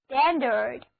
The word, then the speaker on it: standard: 好了，我们在 stand 后面加上 ard 的名词后缀，那么单词 standard S-T-A-N-D-A-R-D standard A-R 字母组合发作 a 单词就读作 standard 标准规格，用作形容词就表示标准的。